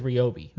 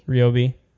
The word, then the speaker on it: Ryobi: We're going to say it correctly, and that is Ryobi. – Ryobi. – And when I say correctly... – We don't want any libel suits. – No, I mean, we know that these guys are going out of their way to pronounce it Ryobi on all of these, like, look at our new products. So, Ryobi it is.